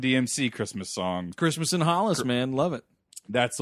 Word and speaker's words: Hollis: it's oh, it's pronounced Hollis.